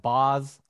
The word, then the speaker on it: Baz: First of all, I recorded an entire Yahoo thing, and I picked Shane Baz as my breakout player. And I guess it's pronounced Boz.